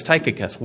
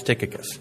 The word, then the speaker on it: Tychicus: So you're thinking, it's not Tychicus, it's Tychicus. Well, according to the app, it's Tychicus.